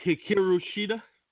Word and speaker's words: Hikaru Shida: Hikaru Shida.